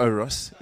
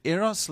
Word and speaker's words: eros: I messed this up recently because of my pronunciation, but there's a Greek word for love called eros love, and I said oros. And so it's not oros, and I might not be saying it correctly, but eros love is a very self-referential love.